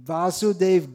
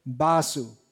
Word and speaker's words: वासु: और बंगाली में उच्चारण है बासु ब व ब जैसे बिहार में भी कुछ लोग ऐसे भोजपुरी में ऐसे भी कहते व ब उच्चारण करते